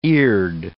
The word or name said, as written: eared